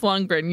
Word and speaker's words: Lundgren: Did you ever see He Man, the He Man movie with Courteney Cox and Dolph Lung- Lundgren? – What's how do you fucking say his name? Uh, Dolph Lundgren. – Yeah. Lundgren. Uh, no, never saw it.